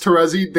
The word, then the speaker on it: Terezi: I've always thought that it was pronounced Terezi. But no, also, it is pronounced Terezi, but I hate to think about it. – It is pronounced Terezi. They spelled it wrong. They spelled it T-E-R-Z-I.